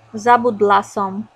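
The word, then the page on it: zabudla som: Slovak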